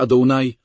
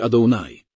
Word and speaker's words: Adonai: A potem nasz Pan pisany małymi literami, ten pierwszy wymawia się Adonai, a ten wymawia się Adonai.